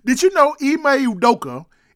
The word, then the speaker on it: Ime Udoka: Did you know that the head coach is it Ime Udoka? – Ime Udoka. That sounds very close.